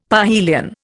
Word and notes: parhelion: Indian

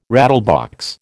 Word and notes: rattlebox: American English

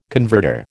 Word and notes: converter: American English

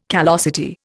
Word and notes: callosity: Indian

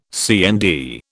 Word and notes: CND: British English

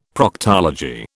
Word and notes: Proctology: British English

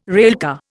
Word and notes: railcar: Indian

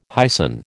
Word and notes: hyson: American English

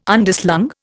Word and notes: Underslung: Indian

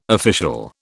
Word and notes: official: British English

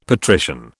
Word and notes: patrician: British English

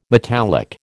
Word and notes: metallic: American English

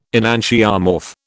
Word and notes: enantiomorph: British English

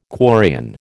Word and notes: quarrian: American English

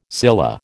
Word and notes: scilla: American English

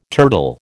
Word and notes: turtle: American English

